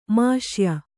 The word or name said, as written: ಮಾಷ್ಯ